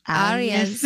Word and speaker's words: Arias: Arias.